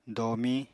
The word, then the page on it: domiñ: apatani